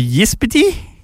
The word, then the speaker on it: Ysbyty: He's climbed onto the roof of, this word is very hard to say. It's spelt YSBYTY. So Yispity Glen Claude Hospital in Boddell, Sweden.